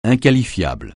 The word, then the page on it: inqualifiable: francais